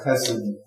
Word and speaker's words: Hesed: And you should have a little more of a guttural Hesed, for that Hebrew pronunciation.